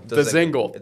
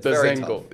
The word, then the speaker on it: Dzingel: They lost three of their top players with Matt Duchene, Sergei Bobrovsky, and Ryan, I can't even pronounce, Dzingel. – Dzingel.